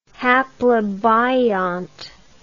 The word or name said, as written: haplobiont